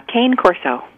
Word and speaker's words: Cane Corso: Is it Canna Corso? – Cane Corso. – Cane Corso. Huh.